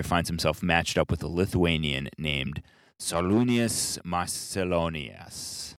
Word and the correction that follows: Šarūnas Marčiulionis: You butchered that very badly. – Sorry. What I meant to say was... – Šarūnas Marčiulionis.